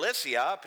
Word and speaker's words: Lycia: Lycia is spelled L-Y-C-I-A, but it's pronounced Lycia. It's just kind of a weird Greek thing.